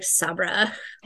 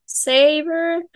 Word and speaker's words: Sabre: And this is spelled S-A-B-R-E. And I was like, Sabra, like in the office. – Thunder Mifflin and Sabre. – Saber. That's exactly what I thought of.